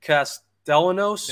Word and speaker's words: Castellanos: I'm not sure if you're familiar with Nick Castellanos, it's pronounced.